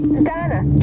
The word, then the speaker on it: Donna: Donna. – Donna. D-O-N-N-A.